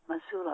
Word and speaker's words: Mosul: is that how you pronounce it? Mosul, Iraq. – Correct.